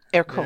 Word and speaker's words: Ercole: Ercole. Yeah, I remember. Yeah, like Ercole. It's like Hercule, like Poirot.